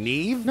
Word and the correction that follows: Nieve: No, Steve Nieve.